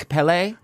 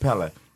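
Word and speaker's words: Kpelle: I didn't want to correct the man because he is a doctor, but it's just Pele. – Pele, okay. So it's all of this. There's okay. No... The K is not pronounced. It's just the Pele tribe.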